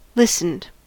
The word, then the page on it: listened: American English